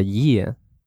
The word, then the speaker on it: year: And then when you've got 12 months, actually that's a year, okay, Y-E-A-R. And just remember, you know, when we're talking in, like, speaking British English, like, we don't pronounce the R, que no se pronuncia la R en inglés británico en la mayoría de acentos. So you say year, don't say year, you know, I'm from California, you say year, okay?